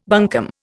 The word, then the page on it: bunkum: Indian